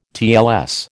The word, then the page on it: TLS: American English